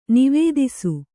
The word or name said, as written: ನಿವೇದಿಸು